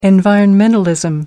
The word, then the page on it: environmentalism: American English